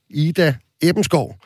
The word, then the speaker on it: Ida Ebenskov: Velkommen til dig, Ida Ebenskov. Er det sådan, man udtaler det? Det er præcis rigtigt.